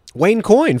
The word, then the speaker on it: Wayne Coyne: I'm sure I'm saying his name wrong. I think it's Wayne Cohn. – Wayne Coyne from the Flaming Lips.